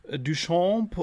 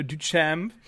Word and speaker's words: Duchamp: Ich bin ja jetzt inzwischen ähm, seit dem letzten Interview klüger und weiß, dass euer Bandname nicht etwa, äh, etwa äh, Duchamp oder äh, Dutch Amp, was ich sehr, sehr gut fand, äh, ausgesprochen wird, sondern äh, Duchamp und genau.